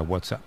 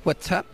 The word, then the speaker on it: WhatsApp: No, peor aún, con acento en la primera. WhatsApp, ¿no? – Un WhatsApp.